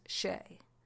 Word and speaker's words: Hsieh: Okay, well, what I found is that it's usually pronounced in English as Shay, but that's not exactly right. That wouldn't really be what it would sound like in Chinese, but I consulted several sources, and they pretty much said to say Shay.